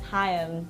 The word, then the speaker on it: Haim: Hey, how you pronounce our last name is Haim. Two syllables.